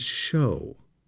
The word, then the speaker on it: shew: Notice in verse 12, that word S-H-E-W is show. That's an old way to spell show, but it's pronounced show. And if that seems strange to you, think about the word sow, S-E-W.